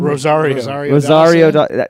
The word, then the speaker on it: Rosario Dawson: Rosario Dawson? – Rosario Dawson.